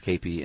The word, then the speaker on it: kepi: Yeah, spelled K-E-P-I, pronounced K-P, and they still wear those.